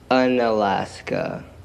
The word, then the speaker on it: Unalaska: How do you say the name of your city? Because I've read it, but I don't know how to say it. – Unalaska. – It's literally called Unalaska? – Yeah.